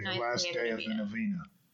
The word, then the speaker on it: novena: So after the novena, is that how you say it? – Yes.